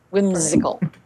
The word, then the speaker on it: Wymzikal: Whimsical, but it's spelled W-Y-M-Z-I-K-A-L. Whimsical.